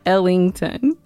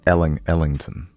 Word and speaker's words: Ellington: You never say it right. It's Ellington. – Ellington.